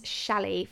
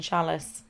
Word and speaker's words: challis: I used a viscose challis from Rainbow Fabrics. – You don't pronounce the S in chalice. You're not supposed to.